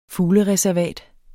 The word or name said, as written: fuglereservat